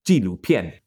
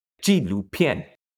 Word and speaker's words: 纪录片: Well, I'll just teach us how to say documentary. – Okay. – Real easy. So, 纪录片. – How do you say that? – 纪录片. – 纪录片. – No. So, J-I-G. – 纪录. – 录片. – 片. – It's they're all the fun. Yep, exactly. So, 片 is the word that you put at the end of any sort of genre.